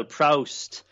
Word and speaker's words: Proust: The barrister deliberately mispronounces Proust. So he says, I've written about Marcel Proust. And Beckett, as he's intended to by the barrister, corrects him and says, in perfect, you know, perfect French pronunciation, a Proust, which already makes him sound A, like a prick, and B, like a kind of Parisian decadent.